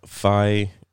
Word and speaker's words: Fi: Now, Fi, it's spelled F-I like Fido.